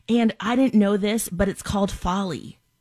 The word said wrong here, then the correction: Foley: Foley? – Foley. – Foley. Foley oh, I, am even saying it wrong.